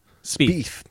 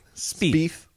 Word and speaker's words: Spieth: There is say his name one more time. Spieth? – Spieth. – Spieth. Good luck, buddy. – Like speed, but a TH.